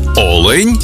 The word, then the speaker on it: олень: І в однині, і в множині наголос падає на перший склад. Олень та олені.